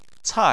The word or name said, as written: cai4